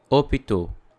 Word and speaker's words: hôpital: Let's have a closer look at the word hôpital. Different from English, the letter H is not pronounced. So, we don't say hôpital, but hôpital. When you pronounce this word, simply forget the letter H.